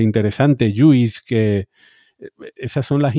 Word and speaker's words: JUICE: Pero, Aunque en inglés se pronuncia juice.